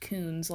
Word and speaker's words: Kunz: Okay, so this is the Koons family murder, and Koons is spelled K-U-N-Z. – Woo woo. – But it's pronounced Koons, like a coon dog.